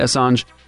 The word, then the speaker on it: Assange: And that's how I say his name, right? Assange? – Yes.